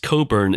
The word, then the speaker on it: Cockburn: You say that, but that was probably pronounced Coburn in the Hyacinth Bouquet way. – You say that. I'd like to think it wasn't. – Yes. Yeah, it's Coburn.